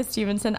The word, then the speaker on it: Stephenson: S-T-E-P-H-E-N-S-O-N. And it is not pronounced Stephenson, even though sometimes I say Stevenson and then I spell it and they say Stephenson.